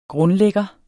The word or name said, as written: grundlægger